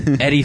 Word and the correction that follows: Edie: Falco for Nurse Jackie. – Edie. You mean Edie? – Huh? – Edie Falco. – Everyone pronounces it Eddie.